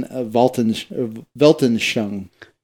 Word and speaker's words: Weltanschauung: He says that the worldview came into the English language through the German Weltanschauung. I think that's how you say it. – Yeah, that's right.